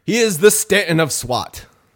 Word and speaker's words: Stanton: See, it's like you look at it and it might rhyme, but it doesn't really when you say it. – You got to say it like Manhattan. Like Stanton, you gotta like Manhattan.